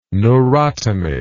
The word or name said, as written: neurotomy